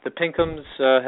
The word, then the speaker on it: Pinkhams: The Pinkhams have been enduring jokes about pink hams for their entire existence, but we have remained true to the original pronunciation,